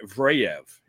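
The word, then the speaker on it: Zverev: And the other one is Alexander Vraev, is the pronunciation.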